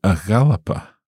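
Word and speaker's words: a dh'Alapa: A Yalapa. How would that be written? – So it's spelled A on its own, mm-hmm. – and then DH apostrophe, and then Alapa. – So a Yalapa.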